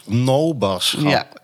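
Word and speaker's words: Noaberschap: Actief in het Nooberschap, zeg ik het goed? – Nee? Nober. – Noberschap.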